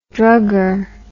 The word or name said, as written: druggery